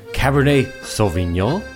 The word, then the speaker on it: Cabernet Sauvignon: Folks, today we're talking about Cabernet Sauvignon.